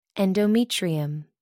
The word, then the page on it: endometrium: American English